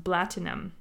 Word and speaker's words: BLTNM: And the name BLTNM is actually pronounced Blatinum. It's in reference to Dubai music institution Platinum Records. So, oh, okay. Because in an Arabic accent, you, instead of saying platinum, you say the P, you pronounce it with a B.